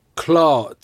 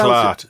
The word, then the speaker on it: clart: Clart. Just pronounce clart. – It. – Clart. C-L-A-R-T.